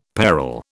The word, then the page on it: parol: British English